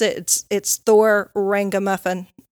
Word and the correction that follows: Ragnarok: Uh, Ragnarok.